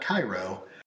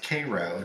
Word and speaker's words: Cairo: It's not Cairo. It's pronounced Cairo.